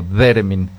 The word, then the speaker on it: Theremin: Es que se escribe TH, es como Theremin.